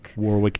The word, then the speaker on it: Warwick: Warwick Davis. – The second W is silent.